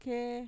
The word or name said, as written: kee